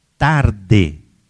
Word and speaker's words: Tarde: O sociólogo chamava Gabriel Tarde. Se escreve como uma tarde, tarde, francês.